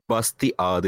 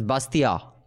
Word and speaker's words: Bastiat: Bastiat. The T is silent. It's a French dude. – The T is silent. Which T? The second T is silent. It's Bastiat.